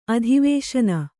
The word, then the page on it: ಅಧಿವೇಶನ: adhivēśana